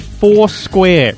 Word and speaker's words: Foursquare: Careful how you say that. – I've said Foursquare. I'm making a conscious effort to pronounce it correctly every time I say it, Andrew.